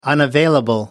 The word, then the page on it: unavailable: American English